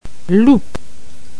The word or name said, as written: loup